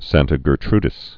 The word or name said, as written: Santa Gertrudis